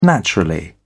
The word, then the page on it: naturally: British English